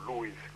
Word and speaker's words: Lewis: Lewis, como se pronuncia correctamente, y la política, ¿no?